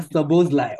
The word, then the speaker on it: Szoboszlai: Yeah, Szoboszlai. Okay, that's the pronunciation. Yeah, Sobozlai.